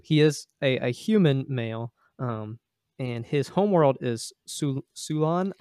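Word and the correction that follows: Sulon: I don't know if I'm pronouncing that right. – I think it's Solan.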